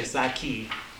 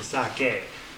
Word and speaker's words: sake: You shouldn't say sake, because that would be an I, but it's a sake.